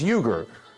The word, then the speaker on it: Uygur: Second name, surname is Uygur. U-Y-G-U-R. Like you... Grr. So don't let it throw you. It's not Yank, Hank, or Plank. Okay, it's Cenk, like Jenkins, Uygur, like Uygur.